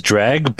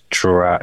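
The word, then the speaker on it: Dreugh: Yeah, I always pronounce it as dreg, but I think in ESO they are pronounced as dro.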